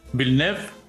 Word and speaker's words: Villeneuve: Que obviamente Villeneuve... lo dije bien. – Muy bien, weón.